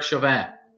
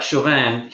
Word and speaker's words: Chauvin: But then you find out the backstory, This guy, I think his name is Chauvin. I don't know how you pronounce it. – Derek Chauvin. – Right, Chauvin.